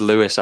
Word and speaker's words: Louis: It's Lewis, actually. I know it's spelt Louis, but I asked you before we started about the surname, and I never asked.